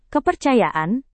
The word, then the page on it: kepercayaan: indonesian